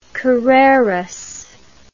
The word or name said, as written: carreras